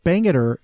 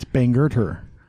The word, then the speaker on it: Bangerter: Because it's not Bangor, it's Bangor-ter.